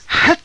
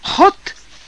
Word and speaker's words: hat: Его взрывное «Хат» — шляпа, «Я никогда не двигаюсь в шляпе даже зимой» отличалось от обычного американского произношения этого слова. «Хот» — именно так произносят его уэндельские горожане,